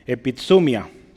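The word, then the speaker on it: Epitsumia (ἐπιθυμία): Epitsumia. Epitsumia. se me olvidan los acentos. Lo voy a escribir para que lo vea cómo se pronuncia, para que usted lo... Hay una tendencia a poner el acento en otro.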